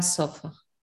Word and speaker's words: sofá: El salón está el en el salón está la sofá. – El sofá. – El sofá. – Sí, entonces acuérdate, hay acento en la A. No, sofá es sofá.